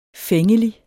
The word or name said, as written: fængelig